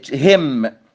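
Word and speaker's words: hymn: طبعا بقى أي كلمة عندنا تنتهي بـ MN ما ننطقش الـ N، كولم يعني عمود، religious song بيقولوا عليها ترنيمة عندهم اللي هي هم، ما ننطقش الـ N، كوندم ان.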